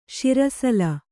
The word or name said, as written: ಶಿರಸಲ